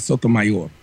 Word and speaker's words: Sotomayor: Even English-wise, you guys pronounce it correctly. It's Sotomayor.